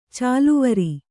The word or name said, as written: ಚಾಲುವರೆ